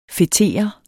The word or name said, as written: fetere